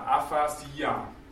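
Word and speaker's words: Aphasia (ἀφασία): Und die Betonung müssen wir bei den Kleinbuchstaben auch beachten, über dem Jota, Aphasia. Wörtlich die Wortlosigkeit, der Sprachverlust, die Sprachlosigkeit. Femi heißt sprechen, Phasis ist eigentlich die Rede, Aphasia, die Sprachlosigkeit.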